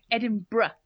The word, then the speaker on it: Edinburgh: When it ends with what it looks like a burrow, it's you pronounce it B-R-A. So, Edinburgh. – Edinburgh? That doesn't make any sense. – No, not brow. Bruh.